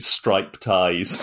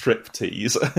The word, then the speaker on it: striptease: And I think one of the guys, probably Alfred, was pronouncing it stripe ties because he hadn't realised it was an English word. And so I said, no, it's American, not English. It's pronounced striptease.